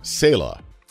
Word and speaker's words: Saleh: And it's Saleh. – That's right. – See, I'm a little disappointed because I actually looked up the Arabic pronunciation. – Yeah. – And it said Saleh.